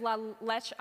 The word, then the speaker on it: La Leche: Like, you either, it's la leche, I always want to say it like it's French. It's not la leche, it's la leche.